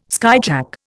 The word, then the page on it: skyjack: Indian